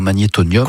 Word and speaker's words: magnétonium: Ce soir-là, donc toujours le 1er octobre, c'est le lancement donc de Rêves électroniques avec le magnétonium. Est-ce que je le dis bien, magnétonium? – Complètement, magnétonium.